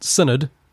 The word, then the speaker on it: synod: Okay, so it's pronounced Synod. That's my bad.